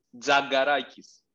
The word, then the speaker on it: Tzagarakis: No. It's Za. Za. T-Z. – This is like Tzagarakis. – Yeah, it's Tzagarakis.